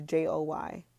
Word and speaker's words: Joy: My name is J-O-Y.